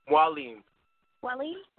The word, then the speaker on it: Mwalim: Mwalim. – Mwalim. – Very cool. – Mwalim. Mwalim, like the French Mwalim, right? – Okay.